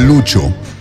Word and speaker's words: Lucio: Se escribe Lucio, ma es Lucho.